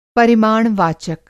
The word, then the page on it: परिमाण वाचक: Hindi